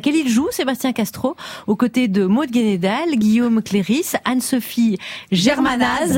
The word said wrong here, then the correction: Le Guénédal: Et c'est Maud Le Guénédal, je me permets. – Pardon. Mais pardon. Le Guénédal.